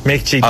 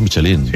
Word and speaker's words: Michelin: Oye, Michelin, ¿cómo se pronuncia definitivamente? – Michelin. A ver, aquí en la Península Ibérica hacemos Michelin.